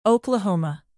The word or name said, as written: oklahoma